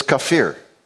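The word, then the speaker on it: kafir (כֹּפֶר): Now, the word for pitch is spelt almost like gopher. It's kafir. Kafir.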